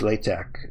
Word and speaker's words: LaTeX: is they use LaTeX, uh, which is L-A-T-E-X, but it's pronounced with a K at the end, and it's it's very much a text-based uh, scripting kind of thing.